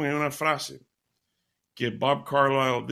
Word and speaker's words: Carlisle: Eh, eh, eh, el, cantante se llama Bob Carlyle, se escribe Bob Carlisle, Carlisle, Bob Carlisle.